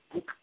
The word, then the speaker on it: Huck: Huck is how they say it over there in Germany.